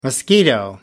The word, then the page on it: mosquito: American English